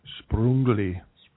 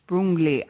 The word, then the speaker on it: Sprüngli: There's another place that we went called Sprüngli. – Sprüngli. I had to say that because I keep saying it wrong.